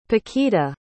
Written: Paquita

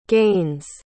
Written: gains

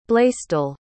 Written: blaisdell

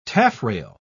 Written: taffrail

